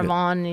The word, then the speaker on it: Vaughan: Sarah Vaughan, io dico, Sarah, però Vaughan.